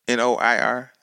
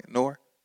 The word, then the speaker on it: Noir: Actually, May 1st, Noir, NOIR. I'm saying that right, Noir. – Noir. – Yeah, Noir means black.